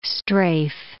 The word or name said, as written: strafe